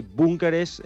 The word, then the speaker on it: búnkeres: Y después de asegurarme bien, te puedo decir que búnkeres es, bueno, lo de la B es bastante... – Está bien dicho. – Sí, está bien dicho. Es con K y es con acento en la U.